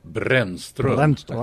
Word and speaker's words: Brannstrom: What's the correct Swedish pronunciation of Brannstrom? – Brannstrom.